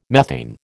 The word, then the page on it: methane: American English